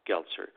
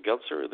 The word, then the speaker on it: Geltser: Y-U-R-I. Yuri. So. U. – And then your last name. – U. Yes. Y-U-R-I. Yuri Gletzer. Gletzer. The family name is spelled like G-E-L-T-S-E-R. Gletzer.